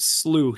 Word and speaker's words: slough: As most of us pronounce it slew here in Kansas. – It's a perennial English discussion in the language. The third pronunciation would have been slough.